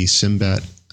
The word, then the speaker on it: Cymbet: Simbet, do you think that's how it's pronounced? C-Y-M-B-E-T. – Yeah, C-Y-M-B-E-T, Simbet, I think is the way it sounds.